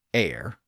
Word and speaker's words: heir: And in the figurative sense, her forehead represents France, because France is making war against its hair, or air, H-E-I-R, depending on the pronunciation.